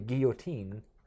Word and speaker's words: guillotine: I'm like, the guy who created his name was Guillotin. Therefore, it's probably guillotine for the pronunciation.